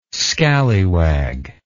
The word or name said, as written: scallywag